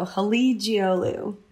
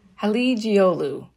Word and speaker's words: Halıcıoğlu: The C is a G and the G is silent. So Halıcıoğlu. – Halıcıoğlu.